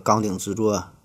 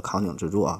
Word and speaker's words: 扛鼎之作: 啊，上期节目我这个纲顶之作这个“ 纲”还读错了，读成了“ 扛鼎之作” 啊！感谢各位听友的指正。